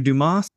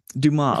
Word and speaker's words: Dumas: Alexandre Dumas, 140. Dumas. Oh, man. Dang it. I even told myself as I wrote this out, the S is silent.